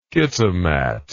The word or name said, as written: kitimat